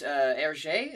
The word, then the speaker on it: Hergé: Hergé.